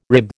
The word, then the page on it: rib: American English